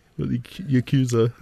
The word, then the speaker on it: Yakuza: The, you've seen the Yakuza, I think.